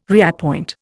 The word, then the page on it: reappoint: Indian